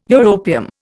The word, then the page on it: europium: Indian